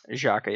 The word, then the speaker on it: Xhaka: I'm gonna say he scores once, Shaqiri scores once, and Xhaka scores once. Is that how you pronounce that? Xhaka, yeah.